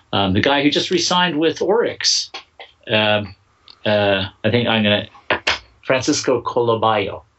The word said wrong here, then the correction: Caraballo: Caraballo. Let's get his name right.